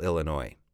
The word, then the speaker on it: Illinois: Remember, Illinois is spelled, how do you spell Illinois? I-L-L-I-N-O-I-S. Please repeat, I-L-L-I-N-O-I-S. Remember, I always insist you need to recognize your letters in English quickly. So Illinois, the S at the end there is silent.